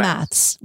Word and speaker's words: maths: how do you say math, like for math class? Is it maths? – Yeah, it is. Yeah, maths with an S.